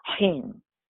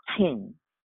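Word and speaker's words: hin (חן): Well, favor in Hebrew, first of all, is pronounced, listen to this, hin. I'll say it again. It's pronounced hin in Hebrew.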